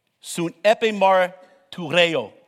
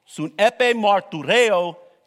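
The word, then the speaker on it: synepimartyreō: Sun epimartureo. Sun epimartureo. That's how you say it. I've listened to it like 20 million times, and I got the R rolling because my wife's Puerto Rican, and I can roll the R's. Tureo, okay?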